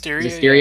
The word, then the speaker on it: Zestiria: I saw a little thing from Tales of Zestiria, is it pronounced? – Zestiria? – Yeah.